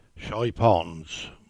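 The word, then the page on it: Chypons: Cornish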